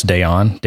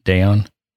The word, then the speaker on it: Dayon: It's, I don't know how to say it, but Alex Dayon, D-A-Y-O-N, Dayon. – Sounds about right. – It's French, so Dayon, probably, right?